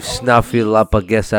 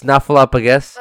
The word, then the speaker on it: Snuffleupagus: Leave Snuffleupagus out of this. – You can't even say it right. – It's Snuffleupagus. – Oh, that's how you said it right.